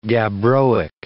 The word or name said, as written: gabbroic